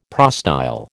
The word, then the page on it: prostyle: American English